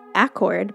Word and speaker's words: Acord: So Westwind Orchard, which is in Acord, New York, which is pronounced Accord, but looks like Honda Accord, is a great place to start.